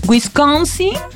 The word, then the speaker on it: Wisconsin: Los estados que estarían faltando: Pensilvania, Wisconsin y Michigan.